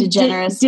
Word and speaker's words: DeGeneres: DeGeneres.